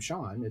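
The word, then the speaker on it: Seán: Yeah, S-E-A-N. Sean. – Oh, wow. – That's like the Irish. – Weird. – Yeah, it's the British. – Irish version of Sean.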